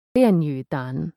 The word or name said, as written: Lianyu Tan